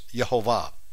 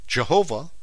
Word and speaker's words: Yehovah: There's so much evidence now to support it that now we know definitively what the name is, and it's not Yahweh, it's Yehovah. Now, the King James had it right. They just spelled with a J instead of a Y, but Jehovah, how you pronounce it in English with a J, but the Hebrew is Yehovah, spelled with a Y, Y-E-H-O-V-A-H. We know how to spell it, we know how to pronounce it, because of the Masoretic Hebrew.